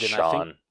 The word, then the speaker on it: Cian: Because you asked a different one, question mark, and then Cian Maher, I hope that I pronounced that right. – I think that's Sean. – Sean, wow, my bad.